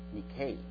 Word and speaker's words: Nike: We do have this word, N-I-K-E, but it's not pronounced Nike. It's pronounced Nikkei.